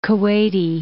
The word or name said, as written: Kuwaiti